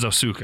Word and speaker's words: Ahsoka: Is Ahsoka. – I think I'm saying it right. – I think you are, yeah.